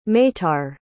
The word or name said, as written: meitar